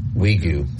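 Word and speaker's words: Wague: Mo Wagi. – Wigu. – Thank you.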